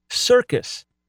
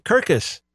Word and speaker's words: circus: Or take the word circus. Doesn't dawn on you that there's a soft C and a hard C because you're used to it. If you're just learning English, you might say Kirkus, right? Or Circus, but most likely Kirkus.